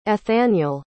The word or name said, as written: ethaniel